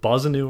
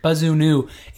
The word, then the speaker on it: Bazunu: B-O-Z-I-N-O-O, if you were to say phonetically, like Bozanu. How can you get Bozanu from is Bazuna? Bazunu. I can understand that mispronunciation, but Bozanu.